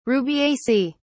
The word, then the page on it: rubiaceae: British English